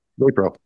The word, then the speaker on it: NaPro: NAPRO? – NAPRO, yeah.